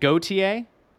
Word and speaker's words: Gauthier: The best case scenario for, for Gauthier is it Gauthier? That's how it should be pronounced.